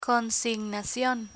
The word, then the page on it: consignacion: Spanish